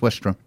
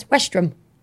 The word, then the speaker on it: Westerham: It's pronounced Westrum. Trust me on this.